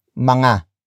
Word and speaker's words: mga: But before that we have mga. Mga. Mga. Mga is spelled as M-G-A.